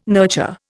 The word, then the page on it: nurture: Indian